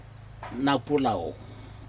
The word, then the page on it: naupulau: Aneityum